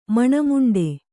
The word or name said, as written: ಮಣಮುಂಡೆ